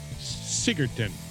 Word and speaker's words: Sigurdsson: The last name is S-I-G-U-R. Keep going. D-S-S-O-N. And how do you say that? – Sigurdsson. – Sigurdsson.